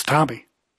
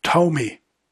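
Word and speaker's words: Thome: It's pronounced Tomy.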